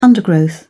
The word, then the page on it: undergrowth: British English